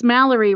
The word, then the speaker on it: Mallory: For instance, Mallory rhymes with calorie, and I've been known to eat a few.